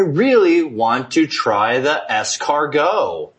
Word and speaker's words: escargot: Escargot.